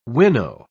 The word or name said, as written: winnow